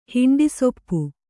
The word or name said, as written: ಹಿಂಡಿಸೊಪ್ಪು